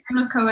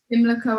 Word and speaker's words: Pimlico: Pimlico Academy in South London. One more time, please. Pimlico Academy in South London.